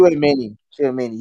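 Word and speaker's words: Tchouaméni: I don't know how to pronounce it. – Chouamani. He's good. – He's good. – I'll vouch for him. He's a good player. He's good. – Chouamani.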